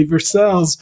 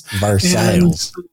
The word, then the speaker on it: Versailles: And it's spelled like Versailles, France, but it's pronounced the Kentucky way, Versailles. – Versailles.